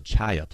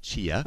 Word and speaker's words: chaya: It's called the chaya plant, not chia, okay. – but chaya. It's spelled C-H-A-Y-A.